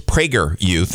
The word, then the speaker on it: PragerU: With a long A, apparently it's PragerU.